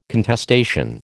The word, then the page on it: contestation: American English